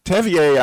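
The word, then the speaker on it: Tevye: I mean, it's a wonderful thing to think, and you know, Tevier, I think that's how you pronounce his name. Is that right, Bethann? – That is.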